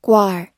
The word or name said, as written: guar